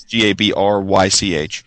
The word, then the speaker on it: Gabrych: So it's been in the last month though it came out, which was Fogtown, written by Anderson Gabryk. I don't know if I'm saying his name right. It's Gabrych.